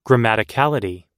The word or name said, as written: grammaticality